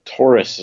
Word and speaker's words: Taurus: Taurus is the way I pronounced it. – Is it Taurus? Like astrological.